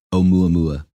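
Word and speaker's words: Oumuamua (ʻOumuamua): Oumuamua.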